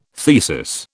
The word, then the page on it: thesis: British English